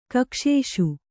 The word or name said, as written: ಕಕ್ಷೇಷು